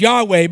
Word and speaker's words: Yahweh: We would pronounce this as Yahweh, but really there were no vowels in between this.